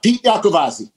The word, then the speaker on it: Pete Iacavazzi: Pete Iacavazzi.